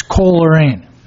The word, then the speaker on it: Coleraine: Mr. Chair, it's Coleraine.